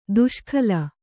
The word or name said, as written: ದುಷ್ಕಾಲ